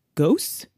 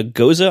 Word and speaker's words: Gose: A goza, I've heard. Goza, yeah, goose? – G-O-S-E. Yeah, I've heard it pronounced almost every way it's possible to pronounce that. But I think goza is the generally agreed upon correct one.